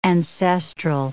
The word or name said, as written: ancestral